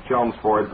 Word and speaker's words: Chelmsford: Well, I was born and brought up in Lowell, and of course Chelmsford is a suburb, and the only person I have ever heard pronounce L in that name was Winston Churchill, and he was referring to Chelmsford, England. But if they pronounce it Chelmsford up there, then we'll pronounce the L's. But honestly, when I say that, I've never heard anybody pronounce the L in Chelmsford.